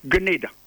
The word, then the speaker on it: Grenada: So the word Grenada is pronounced according to the dictionaries of the English, most outstanding dictionaries of English language since I was a child in the 1960s, which I was taught is Grenada.